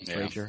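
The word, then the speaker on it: Driedger: Don't know why they don't have Drager. That's his name, right? Drager. – Yeah.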